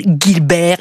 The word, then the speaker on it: Gilbert: Alors, cher Michel, je compatis, ça m'énerve un peu également quand on m'appelle Muriel Gilbert. Et ça arrive assez souvent, je me demande bien pourquoi. G et I, ça fait J.